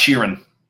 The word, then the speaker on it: Sheeran: It's pronounced Sheeran.